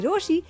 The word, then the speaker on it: Rochie: मेरा नाम on the other hand है मिर्ची रोशी. नाम के स्पेलिंग है R-O-C-H-I-E.